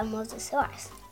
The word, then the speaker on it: Mosasaurus: I got a Mosasaurus. – A Mosasaurus? Am I saying it right? – Yeah.